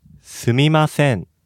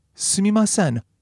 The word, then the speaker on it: すみません: すみません。 – Excuse me. Can you break that down by the syllables? – すみません。すみません。 – Now, すみません roughly corresponds to I'm sorry or excuse me in English.